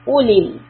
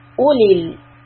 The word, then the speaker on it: أولي: In the next word, أولي, there's a واو after the ألف which we will not read. It is silent. Ulil. Ulil irbati.